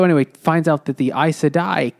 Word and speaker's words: Aes Sedai: So it is Aes Sedai, and I really just pronounced it that way because that's the way I didn't even realize this.